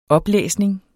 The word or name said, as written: oplæsning